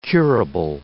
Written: curable

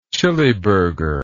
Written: chiliburger

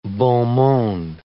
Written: beaux mondes

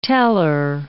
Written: teller

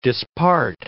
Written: dispart

